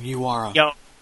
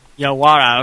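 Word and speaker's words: Yawara: Oh, Yowara. – Yowara, okay.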